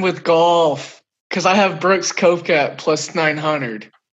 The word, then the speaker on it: Brooks Koepka: I know you've been drinking some Trulies tonight, but his name is Brooks Koepka. That is how you pronounce it.